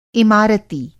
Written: इमारती

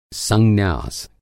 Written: संन्यास

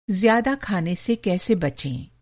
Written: बचना

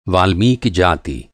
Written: वाल्मीकि

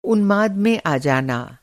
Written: उन्माद

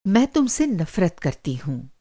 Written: नफ़रत

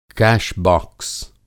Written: कैश-बाक्स

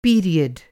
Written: पीरियड

